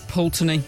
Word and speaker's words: Pulteney: Old Pulteney. – Old Pulteney? – Yeah. – How do you spell that? – P-U-L-T-E-N-E-Y.